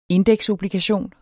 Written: indeksobligation

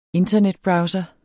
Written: INTERNETBROWSERE

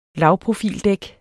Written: lavprofildæk

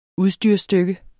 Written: udstyrsstykke

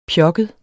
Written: pjokket

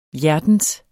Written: hjertens

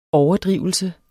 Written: overdrivelse

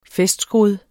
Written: festskrud

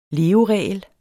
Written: leveregel

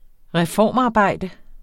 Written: reformarbejde

